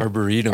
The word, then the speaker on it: arboretum: Cause I do want to talk about the, uh, uh Kendrick Arbit- Arbitum is that right? Did I say that right? Arboretum. – Arboretum. Ar- yeah. Arboretum.